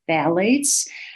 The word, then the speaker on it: phthalates: The pH is silent, so this is phthalates.